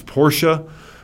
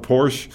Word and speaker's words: Porsche: It's not Porsche. I've heard people who should know better call it Porsche, but it's Porsche. And the Germans just, once again, go absolutely crazy when you mispronounce it. In Germany, it's P-O-R-S-C-H-E, and the E is not silent. You pronounce the E.